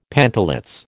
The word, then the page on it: pantalets: American English